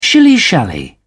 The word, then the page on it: shilly shally: British English